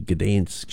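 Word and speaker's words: Gdańsk: And recorded it at the Gdansk shipyard. I suppose that's how you say it, Gdansk.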